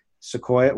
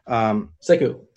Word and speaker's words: Sekou: Like you said, I like Sequoia. What is it? Let's pronounce it. – Oh, Doumbouya. – Yeah, I like Doumbouya. – Sekou.